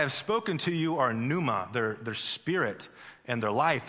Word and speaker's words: pneuma: In the Greek language, it's a pneuma. The P is silent.